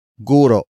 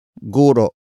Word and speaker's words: guro: It is the focus marker that introduces the main topic of the sentence, and that is guro. Guro. Guro. Guro is spelled as G-U-R-O. Note the glottal stop at the end.